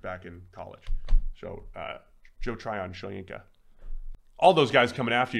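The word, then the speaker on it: Tryon-Shoyinka: Tryon Shoyinka. And I mispronounced his name. – Tryon Shoyinka.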